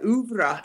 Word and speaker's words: oeuvre: That's now is that pronounced correctly, Sarah? – Oh, mais oui, oui. Mm-hmm. – Okay, yes. – Good. All right, oeuvre, and that kind of means oeuvre.